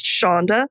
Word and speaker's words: Sandhya: I feel like we could do an entire episode just on names, but my name is pronounced Sandhya and it's spelled S A N D H Y A.